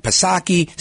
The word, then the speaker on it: Psaki: And this is why I make fun of Jen Pacircleback Psaki, silent P, of course, because she's always saying something and dancing around the issues.